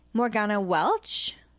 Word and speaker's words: Morgana Welch: Morgana Welch, did I say that right? – Yep.